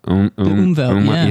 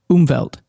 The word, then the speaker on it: Umwelt: Umwelt. Yeah. – How do you say it? Say it again. – Umwelt. – I was close. So it's more like a D at the end, not a T.